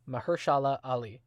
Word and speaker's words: Mahershala Ali: But Uncle Aaron is voiced by the outstanding Mahershala Ali.